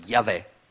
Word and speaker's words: Yahweh: E sì che Abramo aveva già conosciuto Yahweh, quello che noi pronunciamo così, Yahweh, il Dio dell'eternità, il Signore, il Dio di Israele.